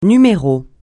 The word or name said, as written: numéro